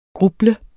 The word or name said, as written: gruble